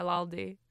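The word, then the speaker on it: laldie: Yeah, laldi. Is that not how you say it? – That is how you say it.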